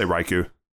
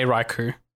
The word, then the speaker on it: Raikou: Raikou? How do you say one? – I say Raikou. – Yeah, I also say Raikou.